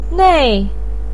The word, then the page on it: nei: 4